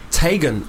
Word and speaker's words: Teigen: Chrissy Teigen is actually meant to be pronounced Tagen or Tigen.